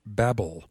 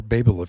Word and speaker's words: Babel: And Ann, today we're going to talk about Babel, or Babel if you prefer. Both pronunciations are correct, from the Tower of Babel.